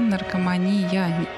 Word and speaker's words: наркомания: Дело в том, что как бы немного путаете окончание, да, наркомания. Некоторые немножко ударение по-другому ставят. Это зависимость, да, от наркотических средств. Не от слова «мен» и не от слова «вумен». – А, то есть... – А от слова «мания».